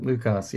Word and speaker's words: Lu Cas: Yep, and I don't know if you heard me kind of hesitate on your name pronunciation again, like I always do, but it's Lucas, right? – You nailed it, Lucas. Yeah, it's Yes. – like a Spanish pronunciation.